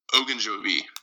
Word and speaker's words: Ogunjobi: With Larry Ogunjobi, I believe that's how you say his name, the huge, massive defensive tackle. – I think it's Ogunjobi. – Ogunjobi, there we go.